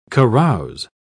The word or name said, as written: carouse